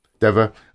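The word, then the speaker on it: Dever: With Caitlin, is it Deva or Diva? I'm not quite sure how you pronounce that. – Deva as Abby, and Ellie love interest Dina with Isabella Merced.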